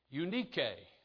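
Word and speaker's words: Eunike: So her name, the first part of it is you, which basically means good. And the second part is nike, unike. That was how she would say her name in Greek.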